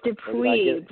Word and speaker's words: Dupuy: Do pie? Is that right, Shae? It's Dupuy, but close.